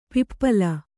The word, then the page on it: ಪಿಪ್ಪಾಲ: pippāla